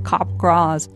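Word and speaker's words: Capgras: Pronounced like Kapgras.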